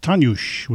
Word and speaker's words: Dagur: It's pronounced Tanush, which is Icelandic for day, but it looks like it's spelled as dagger, D-A-G-U-R.